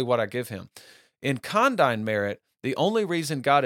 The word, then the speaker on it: condign: The third one is called condign merit, and it's spelled C-O-N-D-I-G-N. Okay, condign merit.